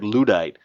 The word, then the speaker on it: Luddite: As far as I know, the pronunciation is Lud. – Luddite. – As in Elmer J. Fudd. Not lewd, as in quaalude, or someone's lewd behavior.